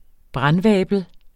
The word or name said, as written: brandvabel